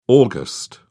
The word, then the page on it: aug: British English